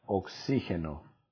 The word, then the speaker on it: oxígeno: En el punto número 4, por ahí me preguntas la palabra oxígeno. Oxígeno lleva acento en la I. ¿Sale? Oxígeno lleva acento en la I y se deletrea O, X, I, con acento, con tilde, G, E, N, O.